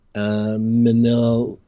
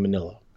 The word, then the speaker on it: Minilla: I mean, I've called him Minia before, but when I was just looking at the spelling, I was like, I've been calling him Manila, like the envelope. – Yeah, because it looks like it. Like, when you read it, it doesn't... – And I was like, maybe Ralph's doing Spanish pronunciation, Minilla? – Yeah, I don't get it, because it's the double L, so that's Spanish, which has nothing to do with the English version or the Japanese version.